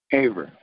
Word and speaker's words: Haver: H-A-V as in Victor, E-R. – Haver.